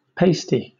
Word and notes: pasty: British English

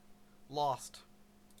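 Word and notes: lost: Canadian English

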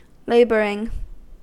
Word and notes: laboring: American English

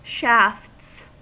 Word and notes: shafts: American English

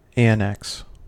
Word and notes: annex: American English

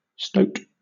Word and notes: stoat: British English

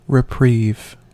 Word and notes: reprieve: American English

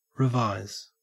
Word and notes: revise: Australian English

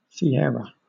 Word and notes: sierra: British English

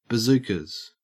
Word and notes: bazookas: Australian English

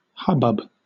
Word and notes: hubbub: British English

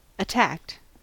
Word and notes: attacked: American English

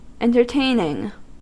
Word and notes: entertaining: American English

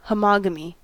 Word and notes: homogamy: American English

